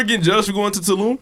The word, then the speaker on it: Tulum: Tulum.